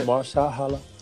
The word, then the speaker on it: Mahershala: Is that how you say it? – Yeah, Mahershala.